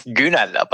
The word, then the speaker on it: Gunal: It's Gunal, but it's really hard to say in other languages.